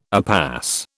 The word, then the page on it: upas: British English